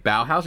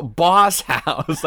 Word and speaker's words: Boss House: So she said Boss House and I thought she just mispronounced Bauhaus. So I was just like, I was like, Bauhaus? And she's like, no, you fucking asshole. Boss House. Like, I'm not misreading the thing.